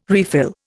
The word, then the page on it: refill: Indian